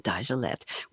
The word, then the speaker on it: Dagelet: I practiced his last name very carefully, and he told me I could use the French pronunciation, Dajalette.